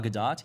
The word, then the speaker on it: Gadot: Gal Gadot. – Yes. – Gadot? – Have any of you, you have to pronounce. You have to pronounce the T.